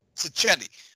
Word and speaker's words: Sacchetti: accusing of, I'm sorry, how do you pronounce his name again, the writer? – Sacchetti.